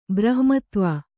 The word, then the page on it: ಬ್ರಹ್ಮತ್ವ: brahmatva